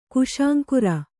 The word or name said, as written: ಕುಶಾಂಕುರ